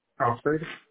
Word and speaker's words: Crawford: Crawford. – C-R-A-W-F-O-R-D? – Yes.